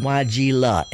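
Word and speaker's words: Weigela: A lot of people say azalea, wisteria, magnolia, hydrangea, but this is Wygella, and ain't no ea on the end.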